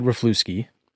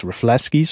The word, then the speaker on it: Rafleski: I said Rafluski, because that's how it's spelled. No. He's just like, yeah, that's the Canadian way of saying it. But it's Rafleski, so I'm trying to work on that because I'm going to meet him at the Canadian Open soon.